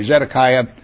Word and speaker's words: Zedekiah: Then the king of Babylon made Mattaniah, who also was called Zedekiah is really a better pronunciation, but anyway, Zedekiah, king in his place.